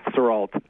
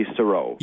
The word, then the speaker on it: Sarolt: It is French, though. Yes, Sarolt. – Sarolt. But see, in French, wouldn't it not have the T pronounced? – In French, it would be Sarolt. – Yeah. – My family gave up on that long ago because nobody got that.